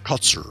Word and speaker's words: Kotsur: Kotsur.